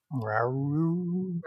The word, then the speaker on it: Rauru: Rora? – Rauru. – Rauru? – Yeah, Rauru. – It's like Scooby-Doo, like Rauru.